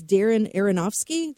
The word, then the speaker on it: Darren Aronofsky: and I can't say his last name, it's Darren Aronofsky. Did I say that right? – Yeah, you got it.